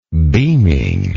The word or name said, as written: beaming